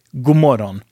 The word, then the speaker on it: god morgon: We really do usually pronounce it like god morgon. I know, you're probably chuckling right now. I can almost smell it. So, yeah. I know, it sounds like good moron, but it doesn't mean that in Swedish.